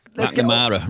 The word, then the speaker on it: McNamara: McNamara. Every time I say it, I always... – It's a learning curve, isn't it? – Yeah. – McNamara rhymes with black banana.